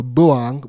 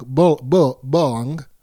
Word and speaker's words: boong: So a boong, let me find this word real quick, pronounced with O like the vowel in bull, so boong, boong, is related to the Australian English slang word bung, meaning dead, infected, or dysfunctional.